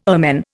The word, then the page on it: ermine: Indian